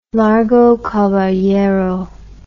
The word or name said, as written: Largo Caballero